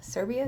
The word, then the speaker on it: Serbia: Brings up her traveling to Serbia. Serbia? Did I say it right? – Yep.